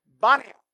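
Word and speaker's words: Banias: It's called Bani. Why? Because Arabs don't pronounce the letter P. So it comes out as a B.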